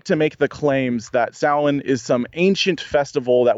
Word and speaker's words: Samhain: Samhain. – Samhain. It's spelled, it looks like Sam Hain, but it's Samhain.